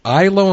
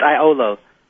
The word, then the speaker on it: IOLO: Is it ILO? Am I pronouncing it right? – It's IOLO. – IOLO. – Yeah, it's spelled I-O-L-O. – I-O-L-O. Makes sense. – I-O-L-O.